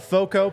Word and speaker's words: FOCO: Guys, check out Foco, pronounced like Fort Collins, but it's F O C O.